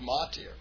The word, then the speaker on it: Motyer: It's not like it how it looks. It's written M-O-T-Y-E-R, but it's actually Mautier.